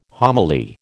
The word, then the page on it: homily: American English